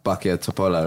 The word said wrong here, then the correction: Bačka Topola: right? Uh, yes, yes. How do you say it again? – Bačka Topola. Bačka Topola.